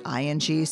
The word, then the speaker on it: Ng: Her Twitter handle is @pronounced_ing because her last name is spelled NG. And you pronounce it ING, so her Twitter handle is actually @pronounced_ING, which is really funny.